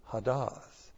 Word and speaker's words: hadas: So what we have here is actually pathak used twice, but they would pronounce this hadas.